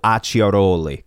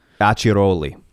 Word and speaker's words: Acciaroli: It's whenever you have a C next to an I, it's pronounced chi. When you have a C-H-I, it's pronounced key. So you have two C's. It's A-C-C-I, so Acciaroli, kind of like Gucci.